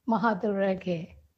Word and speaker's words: Mahadurage: Mahadurage.